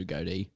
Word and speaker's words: Goad: Know, but like it's spelt Goad. So that's why I don't even bother correcting.